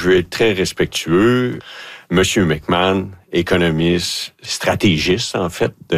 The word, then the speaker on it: McMahon: Sébastien McMahon, de l'Industrielle Alliance. – Parfait. Alors, merci de comme la prononciation. Comme l'ancien corps arrière des Bears.